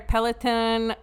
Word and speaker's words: Peloton: And Sad Lake, Egua, he's supposed to go get our Peloton. Is that how you say it? Yeah.